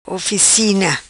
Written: Oficina

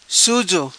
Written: Suzo

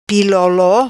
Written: Pilolo

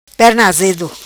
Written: Perna Azedo

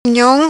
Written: Nhom

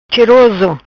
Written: Cheroso

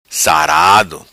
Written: Sarado

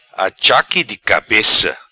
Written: Achaque di cabeça

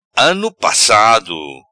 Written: Ano passado

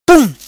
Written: Pum

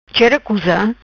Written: Chera Cusa

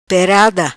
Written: Perada

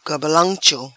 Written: Cambalancho

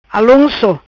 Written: Alonco

